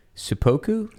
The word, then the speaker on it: seppuku: You should feel great shame and perhaps be forced to commit seppuku. – Seppuku. – Sudoku. By the way, somebody did tweet at me saying that I was pronouncing it correctly and that you are an ass.